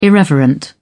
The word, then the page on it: irreverent: British English